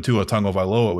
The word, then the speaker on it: Tua Tagovailoa: He was a center at Alabama, and he was used to snapping in the shotgun because he was a center when Tua Tagovailoa was there, which is how you fucking pronounce that.